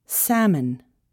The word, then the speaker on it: salmon: The letter L is often not pronounced before the letters L, D, F, M, or K. Calm, half, salmon, talk, would, should.